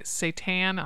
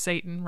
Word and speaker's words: seitan: Joe's, but like so so you know seitan. I don't know if I'm saying that properly. I know not to say it's Satan, right?